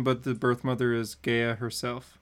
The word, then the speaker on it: Gaia: But in this story, they like say it's, you know, how do I pronounce the Titan's name? Gaia? Gia? – Gia.